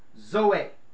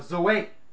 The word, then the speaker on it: zoe: The word is pronounced zoe, or zoe.